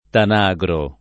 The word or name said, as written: Tanagro